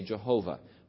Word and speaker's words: Yahweh: Sometimes we say Jehovah, but Yahweh is probably a better pronunciation of the name.